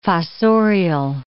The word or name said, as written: fossorial